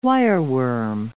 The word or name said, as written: wireworm